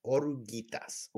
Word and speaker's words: Oruguitas: Which is weird because Spanish, you usually pronounce it's usually like said the way it's spelled, but like it sounds like he's omitting the I or the oruguitas. Yeah, the U. He's omitting the U.